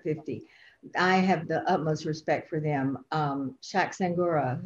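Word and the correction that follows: Shaka Senghor: did I get his name right? He knows that he teases me because I'm always getting his name wrong. But Shaka Sangur,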